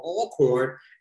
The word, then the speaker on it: Alcorn: So don't, for like the first 2 months, I kept calling Alcorn, it's called Alcorn. And I'm like, who says at all when it's spelled A-L-C-O-R-N? It's no two L's.